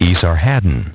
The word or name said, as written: Esarhaddon